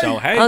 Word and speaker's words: Shohei Ohtani: Shohei Ohtani. – Shohei.